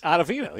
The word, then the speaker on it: Ottavino: Is that how it is? – Ottavino, yeah. – It's Ottavino, I just said it really fast.